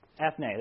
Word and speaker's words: ethne: I'm going to tell you what the word is. It's Ethan, and it means cultures. Ethne. There you go. Greek scholar in the house.